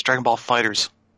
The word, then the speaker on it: Dragon Ball FighterZ: Yeah, the weird thing about that game is that's not how you pronounce the title. It's Dragon Ball FighterZ.